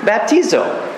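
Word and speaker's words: Baptizo (βαπτίζω): The word baptized comes from a Greek word, baptizo. Pronunciation? – Baptizo. – Thank you. I love having an actual Greek coach for pronunciation.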